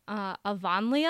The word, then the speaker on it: Avonlea: And so even though I know this is wrong, I always want to call it Avonlea.